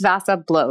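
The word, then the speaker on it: Vasa Blå: which I actually was pronouncing incorrectly in the last two episodes. So it's Vasa Blow is the ones that I've tried out.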